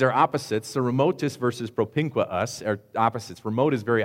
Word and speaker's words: propinquus: If you have 2 vowels in a row, you have to pronounce both of them. So it's super awkward. So you have to say propinquus, okay?